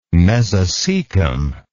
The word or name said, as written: mesocecum